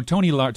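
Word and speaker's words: Tomi Lahren: How do you say your name? Tommy? – Yeah, no, Tommy Lahren.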